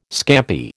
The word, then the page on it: scampi: American English